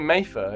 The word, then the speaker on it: Mattha: Mattha.